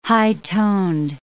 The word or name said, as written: high-toned